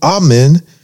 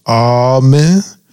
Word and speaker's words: Amen: Amen is derived from the Hebrew amen, which means certainly, truth, and verily. – Yes. – In English, the word has two primary pronunciation, amen or amen.